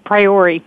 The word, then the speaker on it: Priore: I have Miss, is it Priore? I better make sure I'm pronouncing it right. – Priore. – Priore.